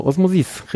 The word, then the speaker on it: Osmosis: Wie spricht man das in Französisch? Osmosis? – Genau, Osmosis, richtig. Das quatschig betonen letzte. Und dann irgendwie die Vokale verstümmeln und schon hast du Französisch.